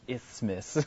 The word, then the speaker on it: isthmus: That's how you pronounce it, isthmus. I don't know why.